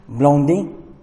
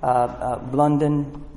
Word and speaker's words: Blondin: How do you say it in French? Someone, B-L-O-N-D-I-N. Blondie. But North American folks say Blunden.